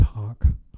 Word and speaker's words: Hach: Yeah, pronounced hawk.